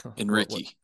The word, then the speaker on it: Enrique: You mispronounced his name, as Houston Nutt would say, Enrique.